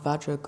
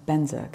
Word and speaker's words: Benza: So you'll also see this as Om Ah Vajra Guru Pema Siddhi Hung or Om Ah Benza Guru Pema Siddhi Hung. I went with Benza because that's the Tibetan pronunciation, is what's on the recordings.